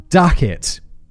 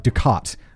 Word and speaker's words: Duckett: In early December 1900, principal lighthouse keeper James Duckett. It's good there's a pronunciation guy, because otherwise I'd pronounce it like Ducat, like that guy from Star Trek.